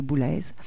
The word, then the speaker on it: Boulez: Pardon, on doit prononcer le Z, donc c'est Boulez.